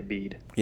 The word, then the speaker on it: Bede: Bede. You meet Bede over there. – Okay, I've never did not think of that one. Okay, Bede.